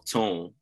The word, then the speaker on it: Toun: it's spelled sorry, it's spelled T O U N, but the way she writes it in her signature, and, and Tone, please correct me, she's like, it's pronounced tone, something like that.